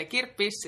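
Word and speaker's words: kirppis: Have I said it right to start with? Yeah, you did. Yeah, kirpis, it comes from the word kirpputori, and it's flea market, where you sell your old stuff.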